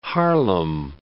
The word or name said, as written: Harlem